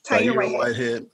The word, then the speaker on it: Tahir Whitehead: And so Shannon, your husband's name is Tahir Whitehead. Is it, am I saying that? I don't want to butcher that. You said it right. – Tahir Whitehead.